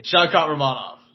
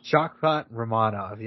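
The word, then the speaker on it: Shavkat Rakhmonov: I'm picking Shavkat Rachmonov, man. I can't even say his name. But Shavkat Rachmonov. – Shavkat Rachmonov, yes. – Yeah, you're getting it.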